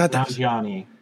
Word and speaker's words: Nanjiani: and it's a true story of Kumail Nanjiani. Did I say that right? – Probably not. – I think it's Nanjiani. – Thank you. Okay, so I wasn't saying the Canadian A.